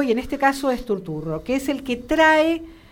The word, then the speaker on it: Turturro: Hay un gran actor, es un actor que yo quiero mucho y que actúa muy poco, que es John Turturro, y se pronuncia así porque es de origen italiano, se dedicó más a la dirección y demás.